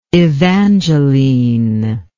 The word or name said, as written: evangeline